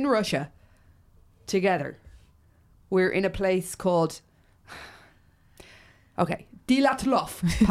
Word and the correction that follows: Dyatlov: Oh my God, your pronunciation is so bad. It took me a minute. – What's yours pronunciation? – Dyatlov. – No, wrong. – They're very similar. – And it's Dyatlov. – Dyatlov.